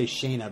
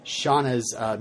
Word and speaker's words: Shauna: I keep wanting to say Shayna because the way it's spelled, Shauna's music video or something.